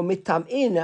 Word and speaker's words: mitam'in: Aval mitma'in, more mitam'in, how's that word pronounced? Mitam'in, eh? – Yeah.